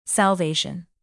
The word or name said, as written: salvation